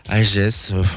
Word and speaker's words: HIJSS: On se quitte donc avec le morceau Dopamine qui va bien porter son nom, des Italiens de H-I-J-S-S, hein, IGS, on prononce comme vous voulez.